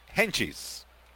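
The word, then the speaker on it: Hentges: Sam Henget, le llaman Henchis, sería la pronunciación en la familia. Sam, de Samuel, de Samuel, y el apellido Henchis. Henchis. Se escribe Henges, con G.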